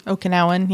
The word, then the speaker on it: Okinawan: The Okinawan, am I saying that right? – Okinawan, yeah.